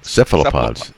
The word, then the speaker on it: cephalopods: Well, they're all called cephalopods. Or cephalopods. – Okay, I had the accent on the wrong syllable there, didn't I?